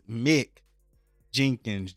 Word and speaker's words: Mick Jenkins: I keep saying it wrong. It's Mick Jenkins. J-E-N-K-I-N-S.